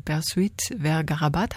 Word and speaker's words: Bersuit Vergarabat: Deshalb haben Sie wahrscheinlich auch den spanischen Titel gewählt, der zum Abschluss dieser Sendung zu hören ist, von Bersuit Vergarabat.